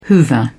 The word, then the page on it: hoover: British English